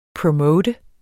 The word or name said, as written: promote